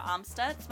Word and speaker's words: Amstutz: And you can find me personally online at Sandra Amstutz. My last name is spelled AMSTUTZ.